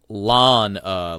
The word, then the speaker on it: Lana: Like Lane-a, like a street lane, or Lan-a, like I'm going to mow my lawn.